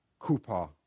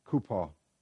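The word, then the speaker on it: kupa (חופה): H-U-P-P-A, or in the Hebrew, it's actually a C in front of it, pronounced kupa, kupa.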